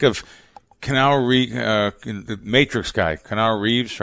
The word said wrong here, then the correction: Keanu Reeves: Am I saying his name right? Keanu Reeves? – Keanu Reeves.